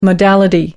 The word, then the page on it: modality: American English